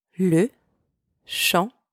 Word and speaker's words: shampoing: Le shampoing. Don't pronounce the G at the end, okay? Le shampoing. Le tapis de bain.